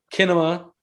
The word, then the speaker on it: cinema: Kinema swappe. – Kinema. – Yeah, because in Latin you don't pronounce C's proper.